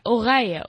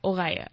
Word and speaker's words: Oreille: I don't think, I think, yeah, Oreille, Oreille? Yeah, Oreille. I think a French person would pronounce it like that.